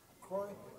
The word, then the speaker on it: Croix: C-R-O-I-X? – Croix. – Croix? Okay, thank you.